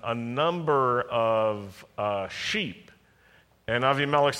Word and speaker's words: Abimelech: Avimelech's servants and the wells of Abraham. There was one point where Abraham met with Avimelech or Abimelech. That's how you say it, Southern style.